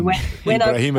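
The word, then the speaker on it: Ibrahimović: Actually, Ibrahimovic.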